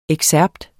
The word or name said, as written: EXCERPTERNE